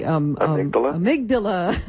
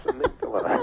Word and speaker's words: amygdala: The amygdala. Yes, amygdala. – Amygdala. It sounds Indian, doesn't it? Sounds like something you should pray to. We're very happy because we sit in meditation in front of the amygdala.